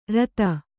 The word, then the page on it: ಋತ: řta; Kannada